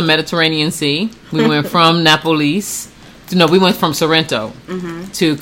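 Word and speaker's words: Napoli: Napolis is how they pronounce it.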